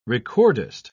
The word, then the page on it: recordist: British English